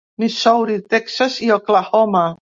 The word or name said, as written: Texas